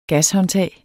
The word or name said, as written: gashåndtag